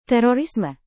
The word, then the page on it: terorisme: indonesian